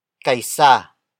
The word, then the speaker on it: kaysa: Kaisa. Kaisa. Kaisa is spelled as K-A-Y-S-A.